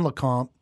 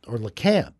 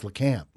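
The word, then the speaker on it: Lecompte: There's not much in LeConte, or LeCamp, actually, is how they say it there. – Really? – Yeah, it's LeCamp. They got two things there.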